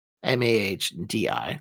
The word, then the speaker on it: Mahdi: So then we get to the 12th and final Imam, Muhammad al Mahdi. M A H D I. It's kind of very hard to pronounce. Mahdi with the H.